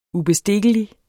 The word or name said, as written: ubestikkelig